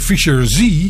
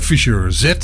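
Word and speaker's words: Fischer-Z: Want die noemde de band dan stug Fisher Z. Maar het is echt gewoon Fisher Z, zo moet je het uitspreken.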